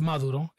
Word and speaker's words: Maduro: Like, and I was like, yo, it's pronounced Maduro. It's pronounced Lejero. It's pronounced, I don't even know what else they say. They say Puro. It's Puro. – Yeah. That's how they say it. I get it. It's like the Americanized way.